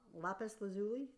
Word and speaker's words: lapis lazuli: Lapis lazuli, is that the right pronunciation? – Yes.